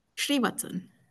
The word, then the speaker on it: Srivatsan: Srivatsan.